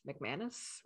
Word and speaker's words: McManus: McManus, right? – Yep.